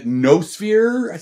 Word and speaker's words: noosphere: Well, in your book, you discuss the concept of, a—I I believe it's pronounced noosphere. Is that correct? – Uh, yeah, I've heard it pronounced different. Uh, noosphere, sometimes.